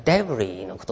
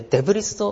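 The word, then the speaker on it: debris: でデブリスってこう聞いた時に僕の頭の中には何が浮かんだかっていうとですね、なんかこうぬいぐるみのちょっとでっかいお腹の出てるリスがね、ちょっとこう浮かんでですね、何言ってるか分かんなかったんですけど、はい、デブリーのことですね、はい。これデブリスと発音しないように気をつけてくださいね。これ S は発音しないですよね。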